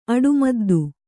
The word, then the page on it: ಅಡಿಮದ್ದು: aḍimaddu